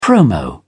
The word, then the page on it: promo: British English